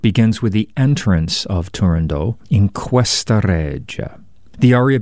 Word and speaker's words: Turandot: but scholars are now almost unanimous that the final T should not be voiced, so pronounce it Turandot rather than Turandot.